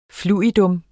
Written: FLUIDA

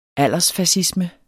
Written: aldersfascisme

